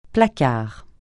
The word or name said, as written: placard